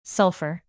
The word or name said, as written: sulfur